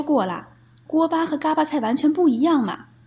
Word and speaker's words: 锅巴菜: 别着急嘛，所谓的嘎巴菜其实写作锅巴菜，只是在天津话当中出现了读音与文字不同的情况。